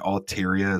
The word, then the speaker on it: Altaria: I also listen to a Pokemon podcast known as The Experience Share, and they're always mispronouncing stuff. And Altaria is one of them. It's Altaria.